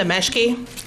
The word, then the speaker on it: Meschke: Madam Chairman and members of the committee, my name is Linda Meschke, and it's spelled M-E-S-C-H-K-E for the record.